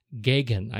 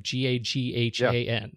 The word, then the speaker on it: Gaghan: his his name is Stephen Gaghan, I believe, G A G H A N, right? Stephen Gaghan.